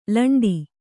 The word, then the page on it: ಲಂಡ: laṇḍa